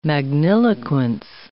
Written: magniloquence

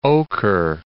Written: Oquirrh Mountains

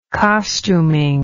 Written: costuming

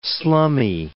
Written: slummy

